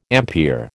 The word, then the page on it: ampere: American English